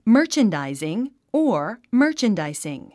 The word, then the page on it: merchandising: mə́ː r tʃəndàiziŋ; マーチャンダイズィン